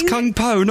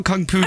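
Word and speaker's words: Kung Pao: It's Kung Po, not Kung Pu tofu.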